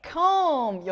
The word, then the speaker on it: comb: Come. – 네, comb.